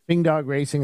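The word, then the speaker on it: Pfing Dog Racing: It has a P at the beginning, and then they said it's silent. The P is silent. Fing Dog Racing and their Porsche Boxster.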